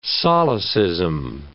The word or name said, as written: solecism